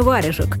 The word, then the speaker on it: варежек: Но ученые больше верят в другой путь появления варежек от наших скандинавских соседей-варягов. Тогда получается значение «варяжская рукавица». Ударение постепенно сдвинулось на первый слог «ва», хотя в карельских и тверских говорах еще встречается обозначение «варяга».